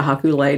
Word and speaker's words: haku lei: A haku lei, is that how you pronounce it? – Haku lei, yeah. – Haku lei. – You know, like a lei that you wear around your neck. So it's haku lei.